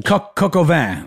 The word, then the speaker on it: coq au vin: What is cocaine? – Oh, I'm mispronouncing it. I'm not French. – Coq au vin. It's chicken with wine.